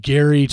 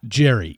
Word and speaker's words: Gerry: His name is Gary Turner, not Jerry, even though it's spelled G-E-R-R-Y. Most people who pronounce their name Gary will spell it G-A-R-Y or G-A-R-R-Y. He's G-E-R-R-Y, yet pronounces it Gary.